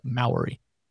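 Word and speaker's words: Māori: Maori.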